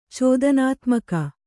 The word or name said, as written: ಚೋದನಾತ್ಮಕ